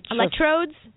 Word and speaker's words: electrodes: They're like these little electrolodes or something. I don't know. – Electrodes? – Thank you.